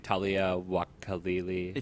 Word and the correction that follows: Taika Waititi: I thought it was Taika. Taika Waititi.